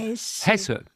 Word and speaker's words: Hesse: Et comme en allemand toutes les lettres se prononcent, ça ne sera pas S, ça sera Hesse.